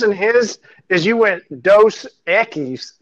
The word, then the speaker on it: Dos Equis: You're saying it right, Dos Equis. You're just Dos Equis. – Okay, cool. – You're just saying it too slow.